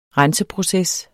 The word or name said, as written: renseproces